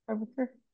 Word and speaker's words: Prabhakar: Prabhakar.